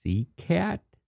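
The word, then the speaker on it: SeHCAT: There's also a third called, I think it's CCAT. It's S, capital S, E, capital H, capital C, capital A, capital T. Is that how it's pronounced? – Yep.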